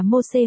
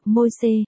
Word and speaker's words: Mô-xê: Moses tiếng Latin, Moses tiếng Việt đọc là Mô-xê hoặc Môi-xê, là một lãnh tụ, nhà tiên tri, người công bố luật pháp theo kinh thánh công giáo.